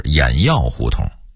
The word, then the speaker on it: 偃月胡同: 应该是劈柴胡同，西河沿的读音应该是西河沿，焦子胡同的读音应该是教子胡同，原来的胡同名是轿子胡同，偃月胡同的读音应该是偃药胡同，白纸坊的读音应该是白纸坊，西园的读音应该是西院，木樨地的读音应该是木樨地。